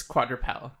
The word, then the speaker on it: quadrupel: or quadrupel? Do you know how to pronounce it? It's Q-U-A-D... – Yeah, it's quadrupel.